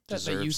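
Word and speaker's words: Yeoh: Oh, and Michelle, How do you pronounce the last name? – Yo. I think it's just Yo.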